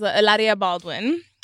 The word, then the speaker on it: Hilaria Baldwin: So, Oscar, the last time you were here, it was Hilaria Baldwin.